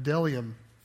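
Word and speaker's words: bdellium: It says, now the manna was like coriander seed, and its appearance like that of delium. And I looked it up, the bee is silent.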